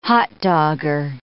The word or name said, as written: hotdogger